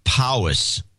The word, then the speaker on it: Powys: He gets my name right, which many do not, but fails to pronounce Powys. English spelling pronounced Powys as in hiss or piss.